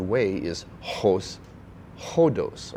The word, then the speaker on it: hodos: Now the word way or road, kind of synonyms, they come from the Greek word hodos. Now it's spelled O-D-O-S, I mean, in our English version, but it's pronounced with a H, an aspirant, hodos.